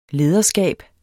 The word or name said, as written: lederskab